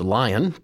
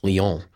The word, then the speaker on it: Lyon: We're going to go to a place that looks like it's pronounced lion, but this is in France, and so we would call it Lyon.